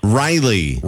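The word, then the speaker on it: Rilee: Riley. – Riley. Like Riley. Riley Green? – Yeah. – That's okay. Cool. Spelled different, though. You spell it with an R-Y? – No, it's R-I-L-E-E.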